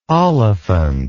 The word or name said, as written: oliphant